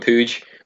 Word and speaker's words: Puig: I think we were talking about this before, how you pronounce it, because it looks like Puig, but I've heard in Barcelona they call him Puig, which is it's dead weird, but we're going to roll with it and call him Puig.